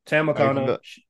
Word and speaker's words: Tam Nakano: Tam Nakano. Is that how you say her name, Tam? – Yeah, Tam Nakano.